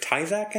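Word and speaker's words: Tyzack: And so Claudius runs in, and Antonia is sitting in a chair, and it's Margaret Tysak, I think is how you say her name? – Yes, that's right.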